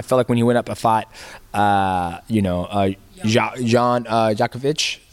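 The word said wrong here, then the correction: Błachowicz: did I say it right? – Blachowicz, I guess. – Blachowicz.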